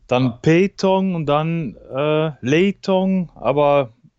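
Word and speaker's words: pétanque: Ja, also das heißt, äh, the proper pronunciation is Petank.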